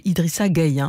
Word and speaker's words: Idrissa Gueye: Donc j'ai bien noté que c'est Idrissa Gueye. Hein. Il faut bien prononcer comme ça.